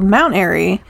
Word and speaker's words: Mount Airy: Called Mount Airy. Which rhymes with Mayberry.